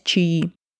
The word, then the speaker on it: чаи: Есть даже такое выражение «гонять чаи». Именно так, с ударением на конечную «и».